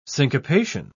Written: syncopation

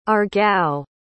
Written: Aargau

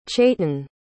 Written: chayton

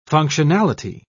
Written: functionality

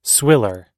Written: swiller

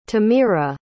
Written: temira

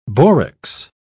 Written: borax

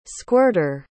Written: squirter

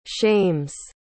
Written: shames

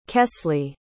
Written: kesley

